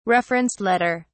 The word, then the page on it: reference letter: British English